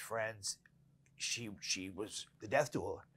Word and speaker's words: doula: But she's also a death doula.